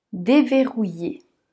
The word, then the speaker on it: déverrouiller: Déverrouiller. Déverrouiller. D. V. ER ici se prononce E. rou Yé. Et ici le I et les deux L se prononcent Y.